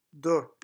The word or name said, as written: deux